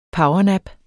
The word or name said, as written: powernap